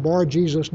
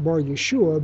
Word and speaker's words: Bar-Jesus: And by the way, I'm pronouncing him Bar-Jesus, not Bar-Yeshua, because this is Greek, and his name may in fact have been pronounced Bar-Jesus as opposed to Yeshua.